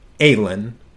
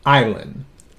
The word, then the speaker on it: Aylin: Even though, yes, they are twins, I believe that name is pronounced Aylin or Aylin, A-Y-L-I-N, and Alfredo Trujillo were born just 15 minutes apart.